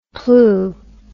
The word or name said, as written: plue